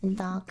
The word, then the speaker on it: Endogamy: Endogamy? Is that how you E-N-D-O-G-A-M-Y? – That's a large word, yes. – Endogamy. – I don't know pronunciation. – It. Yeah. – Endogamy.